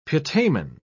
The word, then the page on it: putamen: British English